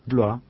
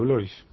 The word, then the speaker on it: Blois: Bloa. – Bloa. – Si querés Bloa es bien pronunciado. Nosotros decimos Chancho Blois. – Yo soy uruguayo, ¿sabes? Viste, francés, porque de origen francés.